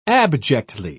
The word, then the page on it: abjectly: British English